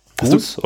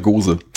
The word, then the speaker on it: Gose: Ist das, spricht man das auf Englisch auch Gose? Ich hätte jetzt Goose gesagt, ehrlich gesagt. Aber Goose ist ja eigentlich ganz, das wird, glaube ich, mit Doppel-O geschrieben, oder?